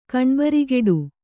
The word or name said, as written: ಕಣ್ಬರಿಗೆಡು